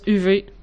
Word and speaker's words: Uwe: Gab, il dit que ça se prononce UV. – Ah. Hein, il y a Gabarnak dans le pod. Dans le chat. – Ça se prononce UV pour vrai. Ça doit être UV.